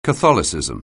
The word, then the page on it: Catholicism: anglais